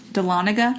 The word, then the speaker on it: Dahlonega: And then the last one is the Smith House in Dahlonega. Am I saying that right? – Yeah. – Dahlonega, Georgia.